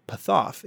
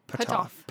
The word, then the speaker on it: Puthoff: So Pat Price would then use his ESP to try and locate Puthoff. And can I see how it's spelled? I'm like really curious. – It's P-U-T-H-O-F-F. Puthoff.